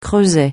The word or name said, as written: creuset